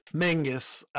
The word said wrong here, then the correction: Magnus: Magnus, not Mangus. Corey. I said it wrong.